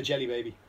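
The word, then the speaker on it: Djelibeybi: And the kingdom is spelled DJELI-BEY-BI. Right? – Yeah. – You look at it when you're reading it, it's like, it's an Egyptian name, fine. But you know when you try and pronounce things out loud? – Yeah. – It's the Jelly Baby.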